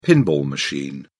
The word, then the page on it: pinball machine: British English